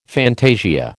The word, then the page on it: fantasia: American English